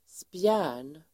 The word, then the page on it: spjärn: [spjä:r_n]